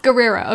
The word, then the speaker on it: Guerrero: I don't know how to say her last name, though. – Guerrero? – Guerrero. Okay, cool. It's Diane Guerrero, right? – Yeah.